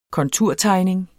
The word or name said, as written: konturtegning